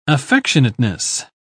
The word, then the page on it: affectionateness: British English